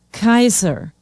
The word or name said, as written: kaiser